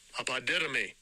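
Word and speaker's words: apodidomy (ἀποδίδωμι): Apao de domi. Let's see how close I got. – Strong's G 591 apodidomy.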